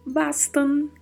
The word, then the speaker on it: bastan: E bastan, que termina com a letra N e não com M. Então precisamos pronunciar de maneira bem adequada.